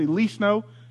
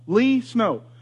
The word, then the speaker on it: Lee Snow: I say, Lee Snow. And they go, Reese? Like Reese's Pieces? No. Lee Snow. Well, how do you spell that? L-E-E, and then the stuff that comes from the sky.